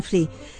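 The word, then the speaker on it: Flea: ¿Te acordás que ayer te hablé sobre, yo no sé si se pronuncia fle o fli? ¿Fli? Ahí está, me está diciendo Ale, me hace ok con fli.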